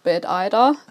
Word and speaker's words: Bad Ida: Genau, also die richtige Aussprache ist Bad Ida, die englische Version.